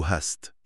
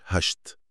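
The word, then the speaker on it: hast: ليه بننطقها هاست بالسين مش هشت؟ – سؤال مهم جداً، وده جوهر القاعدة. القاعدة دي مرتبطة ببداية المقطع الصوتي وبس. – يعني ليها شرط مكاني. – تماماً، لما التركيبة دي تيجي في الوسط أو في الآخر، الإس بترجع لصوتها الأصلي الطبيعي اللي هو سا. – بالظبط، فكلمة هاست بتفضل سا واضحة، والفرق ده حاسم.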